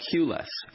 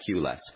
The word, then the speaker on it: Qless: For our listeners, Qlits is spelled the letter Q, L-E-S-S, but a cute play on words, of course, as you're all about reducing the need for a queue or a lineup, as we say here in North America.